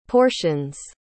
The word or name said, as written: portions